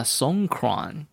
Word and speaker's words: Songkran: Songkran, yeah. – Yes, you have to pronounce it the right way, the Thai way.